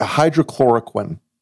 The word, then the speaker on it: hydroxychloroquine: Dr. Trump's miracle elixir, hydroquacawacala. – Whatever. Hydro, you know I can't even pronounce it. – Hydrochloroquine. Right? – Hydrochloroquine, yes. So they all wanted to talk about hydrochloroquine, even though this hearing wasn't even about that.